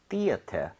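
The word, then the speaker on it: theatre: TRE도 가능합니다. 그리고 발음도 심지어 트레가 아니라 ter 맞아요. – 그래요? – 네. 근데 오. – 영국식 스타일입니다. So, 이거는 theater이라고 발음을 한다면 얘도 theater 해도 되지만 만약에 이렇게 스펠링을 한 사람은 조금 영국식으로 가겠다는 느낌이 되죠. So R 발음을 빼서 얘는 theater, theater 뭐이 정도로 발음이 될 텐데 굳이 그렇게 안 해도 됩니다.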